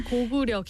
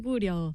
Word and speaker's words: Goguryeo: And believe it or not, beekeeping has been practiced in Korea for over 2,000 years, dating way back to the Goguryeo kingdom. – Yeah, it's Goguryeo kingdom. – There you go. – You pronounce it right. – Look at that. – Goguryeo.